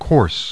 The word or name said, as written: course